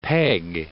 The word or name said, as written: peg